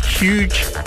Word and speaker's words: Hooj: The label I am talking about is Huge Tunes. It's spelled H-O-O-J.